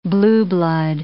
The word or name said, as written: blue blood